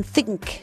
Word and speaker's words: Thicke: Pour bien le prononcer, il faut mettre la langue entre les dents. Le Robin Thicke. – C'est comme Bluetooth.